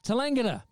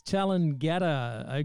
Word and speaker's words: Tallangatta: I'm actually in Victoria, uh, Tallangatta. I was pronouncing it as Talangata, okay? T A L L A N G A T T A.